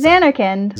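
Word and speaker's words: Zanarkand: How do you pronounce Xanarkand.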